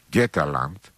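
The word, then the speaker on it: Götaland: Η νότια Σουηδία που είναι κοντά στο Γκέτεμπορκ λέγεται Γκέταλαντ. Γκέταλαντ, σας το λέω και με σουηδική προφορά, είναι η χώρα των Γότθων.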